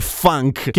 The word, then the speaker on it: Funk: Quindi qualcuno si reinventa le vitamine, le riscopre nel 1912 a Londra il buon Casimir Funk, che ha un nome fichissimo che in realtà però si pronuncia funk perché non è inglese ma è polacco.